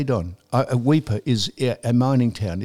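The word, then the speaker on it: Weipa: Exactly, if you're going to advertise where Indigenous Literary Day is, it's Weeper. Wouldn't you think you'd be able to pronounce the country town properly? Weeper. He said Wiper.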